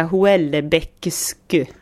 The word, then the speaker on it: Houellebecq: Det stavas alltså Houellebecq. Och så uttalas det Houellebecq ungefär som att det stavas så här Houellebecq.